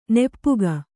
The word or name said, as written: ನಿಪಕ